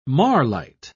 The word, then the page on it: marlite: British English